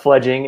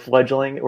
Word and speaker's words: Fledging: Fledging is what we are. A lot of people go with fledgling.